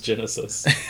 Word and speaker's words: Genisys: Is that how they pronounce that in the movie? – Genisys. – Because I'm pretty sure it's Genesis. – No, it is Genesis.